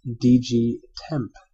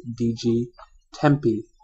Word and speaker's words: DG Tempe: So let's begin with DG Temp, as I had always pronounced it. Benevolent Heretic has stepped in and informed me that it is DG Tempe, in fact, as in Tempe, Arizona.